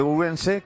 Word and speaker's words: Ourense: compañera de COPE Ourense, que nos ha... ¿Qué he dicho? He dicho mal Ourense, ¿no? – No, Ourense lo he dicho francés. Con pronunciación Ourense.